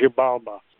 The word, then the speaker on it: Xibalba: Let's talk about another one of the longer pieces that you have, Operation Zalba. Is that how you pronounce it? Yeah, Xibalba. Zibal, the, the X is, is G. It's Xibalba. It's a, a Mayan name.